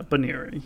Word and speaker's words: Buneary: So, Buniri, B-U-N-E-A-R-Y, clearly an ear thing going on, Buniri, I just wasn't quite sure how to pronounce that. – Yeah, I was listening a little bit, and it's, yeah, Buniri, which is a bit of an odd name.